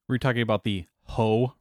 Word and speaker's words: Hoh: So it's pronounced Ho. – As in slap the, yeah. – Yeah, H-O-H.